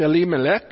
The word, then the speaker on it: Elimelech: And the name of the man was, it's actually pronounced Elimelech.